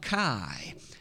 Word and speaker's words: chi: That first letter looks like an X, is actually the Greek letter called chi. And in fact, it's pronounced k, as if it's a ch kind of pronunciation. And so indeed, that's the word for the Christ.